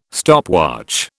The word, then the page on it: stopwatch: British English